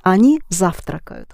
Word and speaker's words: завтракают: Yes, the first syllable accented. Они завтракают.